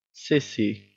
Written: cissy

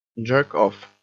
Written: jerk off

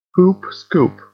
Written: poop scoop

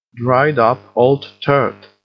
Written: dried up old turd

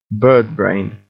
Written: birdbrain

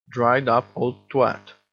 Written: dried up old twat

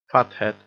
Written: fathead

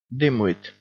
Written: dimwit

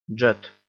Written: jet

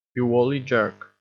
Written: you wally jerk

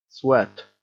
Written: sweat

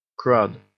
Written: crud

